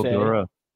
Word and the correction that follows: Daria: No, Daria. There's only one way. There's Daria. Daria. Daria. Like the MTV show.